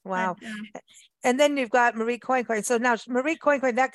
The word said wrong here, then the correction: Coincoin: Marie Therese Quanquan was born. – Quanquan, I'll say it correctly. Quanquan.